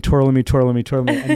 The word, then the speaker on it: Tourlami: did I say that right? – No. – Tourlami.